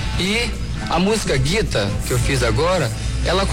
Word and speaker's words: Gita: A gente fala Gita, mas não é Gita não, viu? É Guita. Pronúncia correta é Guita.